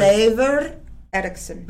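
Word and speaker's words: Leifur Eiríksson: And was that Leif Erikson? – Leif Erikson. – Come on. None of you knew I knew the history like that. But how do you say pronounce it correctly? – Leifur Eriksson.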